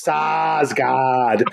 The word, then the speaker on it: Sarsgaard: Well, you have to say his name correctly because you have to pronounce it with all the A's in it. It's Sarsgaard.